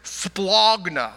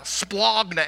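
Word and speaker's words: splagna (σπλάγχνα): The word is, the Greek word is splagna, splagna. And you have to say it like it's a very guttural word.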